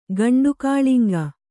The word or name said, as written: ಗಂಡುಕಾಳಿಂಗ